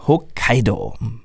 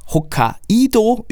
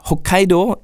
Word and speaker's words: Hokkaido: In Hokkaido. Nicht Hokkaido übrigens, wie es in Österreich gern mal ausgesprochen wird, ähm, Hokkaido ist Japans zweitgrößte Insel und ihr Name bedeutet übersetzt Nordmeerstraße.